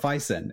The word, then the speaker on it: Vaesen: Am I pronouncing the name correctly? – Yes, I believe you are. This has been something that I jump around on. I suppose as the subject matter expert, I do have a background in Latin, Old Norse, Old English, and there's a bazillion ways to say that A-E, so it's spelled V-A-E-S-E-N, so I will probably say Vaesen, Vaesen, or some combination thereof.